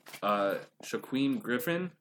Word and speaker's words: Shaquem Griffin: Okay, so I want to talk about a UCF player, Shaquem Griffin. Is that, I say that right? – Yeah.